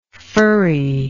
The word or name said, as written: furriness